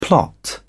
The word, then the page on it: plot: British English